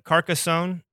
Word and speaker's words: Carcassonne: And so when I'm looking at like some of your top choices, so, and I'm pronouncing this right, I hope, Carcassonne.